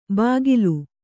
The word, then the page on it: ಬಾಗಿಲು: bāgilu